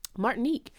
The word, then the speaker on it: Martinique: So they took out that whole part and then parts of, is it Martinique? Is Martinique. That how you say it? – Yeah. – Martinique.